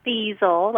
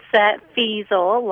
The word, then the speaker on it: Feisel: And I just want to say that the last name is Feisel. That's okay. It gets mispronunciated a lot. So it's Feisel, like easel, only with an F in front of it.